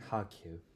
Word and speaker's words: Kharkiv: So Kharkov is a Russian name. Kharkiv is what we English speakers use. It actually sounds like Kharkiv.